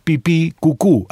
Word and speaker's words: pipicucu: El Facebook es pipicucu. Así nomás, pipí con acento en la segunda I. Cucú con acento en la segunda U.